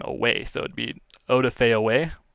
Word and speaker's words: Odafe: Odafe. Odafe. Odafe. is the way Odafe.